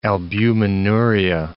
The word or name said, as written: albuminuria